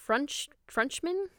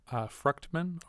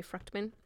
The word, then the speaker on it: Fruchtman: And then we have Lisa Frenchman. Or I might be pronouncing this wrong. – Fruchtman? Or Fruchtman? – Yeah. – Maybe Fruchtman.